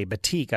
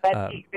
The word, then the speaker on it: batik: B as in boy, A-T-I-K. Batik, I think, is how I've always heard it pronounced. – Batik, there we go. – Batik Wax.